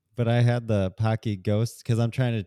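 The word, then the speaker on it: Paqui: In, I think it's pronounced Pocky. I think it's P A Q U I.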